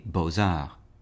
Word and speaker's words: beaux-arts: For example, with two words such as beaux, the first word is spelled B-E-A-U-X, and the second word is A-R-T-S, but we hear the Z sound from the X connecting to the A, beaux-arts.